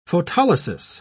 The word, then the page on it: photolysis: British English